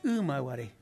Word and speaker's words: Imaguaré: En realidad la palabra está castellanizada porque en rigor la pronunciación correcta es Imaguaré. Ah. Así como es correcto decir Uberá, ¿no es cierto?